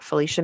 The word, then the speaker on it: Phylicia: And then my most popular spot for discussions is Instagram, and I'm at Felicia Masonheimer there.